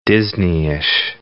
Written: Disneyish

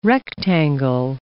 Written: rectangle

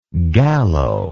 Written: gallo

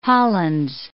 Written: Hollands

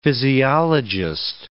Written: physiologist

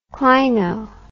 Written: clino

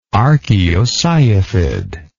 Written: archaeocyathid

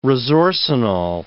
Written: resorcinol

